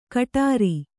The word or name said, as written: ಘುಟುರು